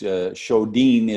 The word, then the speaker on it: Sjodin: It's spelled S-J-O-D-I-N. It's Swedish. So the J is like an H, Sjodin.